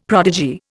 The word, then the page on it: prodigy: Indian